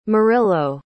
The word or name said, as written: murrillo